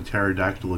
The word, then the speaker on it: pterodactyl: Why can't you tell if a pterodactyl is using the bathroom? Because the P is silent.